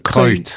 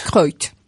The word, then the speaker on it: Kreuth: En Bavière, la plus ancienne tradition équestre liée au culte de Saint-Léonard a eu lieu dès le XVe siècle, en 1442, à Kreuth. Je ne sais pas comment ça se prononce. – Kreuth. – Kreuth.